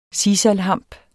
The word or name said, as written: sisalhamp